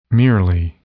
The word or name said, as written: merely